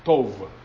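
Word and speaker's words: Tov (טוב): Porque la palabra que se utiliza para bueno es la palabra tov. Así se pronuncia en el hebreo, pero se escribe T-O-B. T-O-B. Tov.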